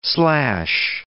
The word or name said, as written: slash